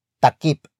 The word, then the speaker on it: takip: It comes from the root word takip. Takip. Takip. Which is spelled as T-A-K-I-P.